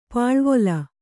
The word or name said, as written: ಪಲವಲ